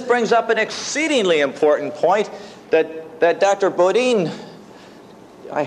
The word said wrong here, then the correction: Bodian: hate to say this, am I pronouncing that right, Bodin or Bodian? – Bodian.